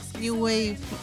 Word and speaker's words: New Wave: Sí, hoy traemos un grupo formado solo por mujeres y ampliamente conocido por ser originarias del V-Wave. – New Wave. – Perdona, por mi pronunciation.